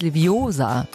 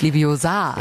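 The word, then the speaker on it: Leviosa: Es heißt Liviosa, nicht Liviosa.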